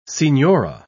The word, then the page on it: signora: British English